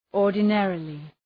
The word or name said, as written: ordinarily